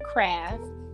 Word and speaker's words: Craft: Well, my Facebook name is Satavia Craft, um, I don't know if I need to spell that.